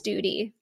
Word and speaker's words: Struczewski: It looks like study. So Kaitlyn, S-T-U-D-Y, like study, but pronounced Studi. – Yeah, because I wrote on my notes here, Kaitlyn, S-T-O-O-D-Y, so I wouldn't mispronounce your name.